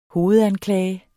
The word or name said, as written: hovedanklage